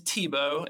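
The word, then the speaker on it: Thibault: So his name is Tebow, and it's spelled like Thebalt, but he's a really awesome guy.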